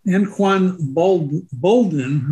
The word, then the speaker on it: Anquan Boldin: Now, one that may come up in the future is Anquan Boldin. Hope I pronounced that right. – Yes.